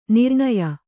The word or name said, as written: ನಿರ್ಣಯ